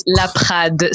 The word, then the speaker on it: Laprade: Yeah, kinda. You added some style to it. It's very French. It's Laprade. So of course, Laprade is like, flavor.